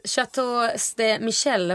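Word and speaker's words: Chateau Ste. Michelle: Chateau de Michel, om jag uttalar rätt. Visst är det? Ja, absolut.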